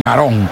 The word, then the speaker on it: Arão: Los brasileños dicen Arón, pero bueno, nosotros tal como se escribe, Arão, gran jugador, el de hombre de la melena, tiene el número 15.